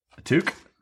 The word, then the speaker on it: toque: It's T-O-Q-U-E normally. – Yeah, but it's also you can like in French it's T-U-Q-U-E, and I feel like that's closer to like how you would. Pronounce it.